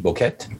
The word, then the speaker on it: Boquete: I just lost the name of the town we've been hanging out in, but Boticate. I'm saying it wrong. – Right, anyway, now. No, yeah, it looks like it's a Boquete.